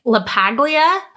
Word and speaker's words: LaPaglia: It's starring Anthony LaPaglia, I think is how you say it.